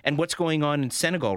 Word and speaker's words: Senegal: Is that how you pronounce it? – Yeah, Senegal. That's right.